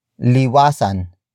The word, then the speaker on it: liwasan: And that is liwasan.